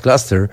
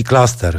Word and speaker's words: Cluster: Up and Down to jedna z 13 kompozycji wytłoczonych na dwupłytowym albumie City Cluster, choć przyznam Państwu, że wymawiając tytuł chce mi się powiedzieć Cluster, czyli tak jak nazwa niemieckiego zespołu, o którym oczywiście mówi się Cluster, ale jakoś intuicyjnie niemiecka wymowa znacznie bardziej mi tutaj pasuje.